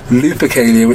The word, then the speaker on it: Lupercalia: They probably come from an ancient Roman festival called Luperlica, or Lupercalia, say it right, probably both wrong, Lupercalia, which took place every February the 15th.